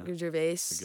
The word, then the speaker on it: Gervais: Gervais, little comedy skit that he's been testing out.